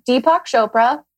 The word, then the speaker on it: Deepak Chopra: It's from Deepak Chopra. Did I say his name correctly? – You did.